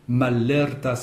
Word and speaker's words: malerta: Et il faut bien prononcer les deux L. – Oui, malerta. – Il y a le L du préfixe mal, le contraire, et le L de la racine l'erte, donc.